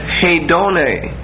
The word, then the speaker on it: hedone: The Greek for lusts is hedone. H-E-D-O-N-E. H-E-D-O-N-E. And both E's are long E's. They're adas. So it's an A sound.